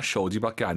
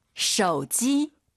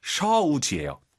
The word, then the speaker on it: 手机: 쇼지 쇼지 아니죠? – 手机。 – 오유는 어우예요. 굳이 한글로 표기하면 쇼지가 아니라 셔우지예요. 들어볼게요.